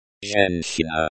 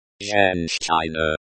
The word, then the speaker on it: Женщина: Pronunciation. Женщина. Женщина. The ZH sounds like the S in pleasure. The CH is like the CH in chair.